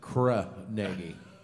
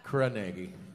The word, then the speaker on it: Carnegie: In that my hometown, they pronounce it Carnegie. Carnegie. – Carnegie. – Cool. Not the official Scottish pronunciation.